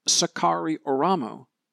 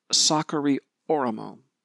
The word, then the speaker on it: Sakari Oramo: His name may look Japanese to you, and you may want to pronounce it Sakari Oramo. He is, in fact, a Finn whose name is pronounced Sakari Oramo.